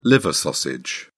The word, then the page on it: liver sausage: British English